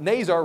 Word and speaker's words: Nazar: But Nazar rhymes with razor.